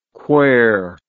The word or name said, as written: quair